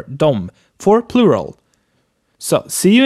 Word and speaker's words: dem: Dem is mostly pronounced like dom, like its subject counterpart, de. But you write D-E-M.